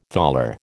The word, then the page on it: thaler: American English